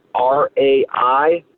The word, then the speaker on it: Raiklin: My last name is spelled Raiklin.